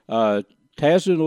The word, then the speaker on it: Tazin: But Tazin Lake Lodge, T-A-Z-I-N, that's the way it's spelled.